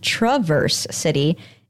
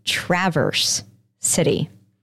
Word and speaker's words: Traverse City: Like in the word, the A makes an schwa for my teachers sound. But for the city, they say the A like an actual short A, like in cat. So it's not Traverse City, it's Traverse City.